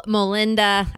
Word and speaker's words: Molenda: Rachel Molinda, I think I said it right that time.